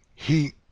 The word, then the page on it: gi: Spanish